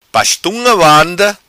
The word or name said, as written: vanda pa estunga